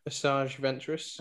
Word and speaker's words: Asajj Ventress: Asajj Ventress.